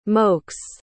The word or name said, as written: mokes